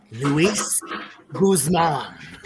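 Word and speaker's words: Luis Guzmán: Louise. – Joker, say it correctly. – Luis Guzman.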